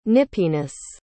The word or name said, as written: nippiness